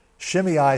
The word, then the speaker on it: Shimei: His name was Shimei. That's how you pronounce that name. Shimei, son of Gera, and he cursed as he came out.